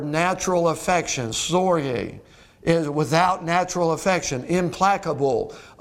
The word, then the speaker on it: storge: It is the word storge. Storge, and you really have to pronounce it with the H-E-E-E rather than the G, but it's spelled S-T-O-R-G-E.